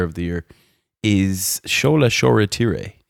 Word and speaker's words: Shoretire: So Shola Shoretire, again, it's spelt Shoretire, T-I-R-E, but we're fairly sure on the Shoretire, so let's stick with that. – It's Shoretire, yeah.